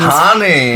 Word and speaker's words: Honey: Du musst das Englisch aussprechen. – Honey.